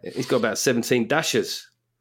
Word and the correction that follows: dacha: Not the right pronunciation, but good knowledge anyway. – So explain what a dasher is. – Dacha.